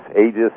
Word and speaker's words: Aegis: I guess you call it, Aegis? – Aegis, it's pronounced both ways.